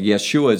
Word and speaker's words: Yeshua: Yeshua is his Hebrew Aramaic pronunciation of his name, which is Joshua in English.